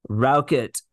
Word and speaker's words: Rauchut: Rauchut.